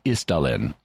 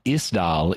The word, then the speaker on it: Isdal: In any event, Isdal is the ice dale or the ice dell, and the Isdalen woman in Norwegian is thus the ice valley woman.